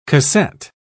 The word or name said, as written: cassette